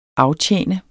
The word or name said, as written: aftjene